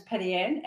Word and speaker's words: Patty-Ann: My first name is Patty-Ann, and my surname is Waho.